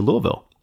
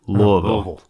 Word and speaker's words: Louisville: When he was 7 years old, the family moved to Louisville. – Okay. – I have to say it the right way. Louisville. – It's like a two-syllable word.